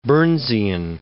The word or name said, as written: Burnsian